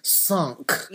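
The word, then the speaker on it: cinq: And then starting January 1st is season, how do you say five in? – Sunk. – Sunk.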